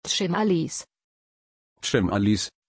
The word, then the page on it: chimalis: German